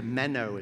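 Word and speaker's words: meno (μένω): Because the Greek word here is a meno, is how it's pronounced, uh, kind of like the little fish that swim that you use for bait.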